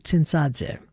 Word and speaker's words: Tsintsadze: Sulkan Tsintsadze, I was going to say that correctly, Tsintsadze, was a 20th century Georgian composer known for his chamber music and his film scores.